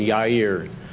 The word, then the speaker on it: Jairus: the J would be a Y sound, so which is a common Hebrew name, Jairus.